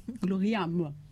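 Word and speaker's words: Ad Majorem Dei Gloriam: Donc, la devise de cet ordre, c'est Ad Majorem Dei Gloria. J'ai bien prononcé ? – Oui. – Gloriam. Oui. Qui signifie tout pour la plus grande gloire de Dieu.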